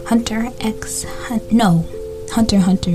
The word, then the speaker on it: Hunter x Hunter: In today's episode, we will be talking about Hunter x Hunter, because you don't pronounce the X.